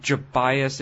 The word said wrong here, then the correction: Gobias: Gobias, as in go buy us some coffee from Arrested Development. – You say, wait, it's pronounced Gobias?